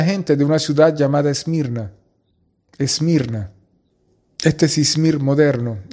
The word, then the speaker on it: Esmirna: En la palabra Esmirna puedes escuchar mir en ella. Esmirna viene de la palabra hebrea mir, se pronuncia Esmirna en griego, y significa amargo, amargura.